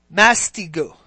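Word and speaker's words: mastigo (μαστιγόω): P-A-I-D-E-U-O with an accent. And the other word is mastigo. M-A-S-T-I-G-O-O, accent on the O.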